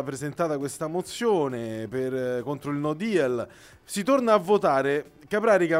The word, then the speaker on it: no deal: Mi permetta anzitutto di correggere la pronuncia, ma lo faccio non per lei, ma siccome sento che gli italiani hanno trasformato il no deal in no deal. No, è no deal.